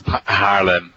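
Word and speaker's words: Haarlem: Am I saying this right? In Harlem, or Harlem in the Netherlands? – Harlem.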